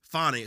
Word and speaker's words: Fani: First of all, the prosecution, which is weird in today's format because Fannie and Mr. Wade Fani, excuse me, I always mispronounce her name.